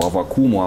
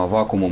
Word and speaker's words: Аввакум: Но вот возвращаясь к метафоре, к уподоблению протопопу Аввакуму, Аввакуму мы тут сейчас как раз разбирались, собственно, два ударения, да, потому что старое библейское – это Аввакум, а раскольники и в то время, и потом раскольники говорят Аввакум.